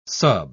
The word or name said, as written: sub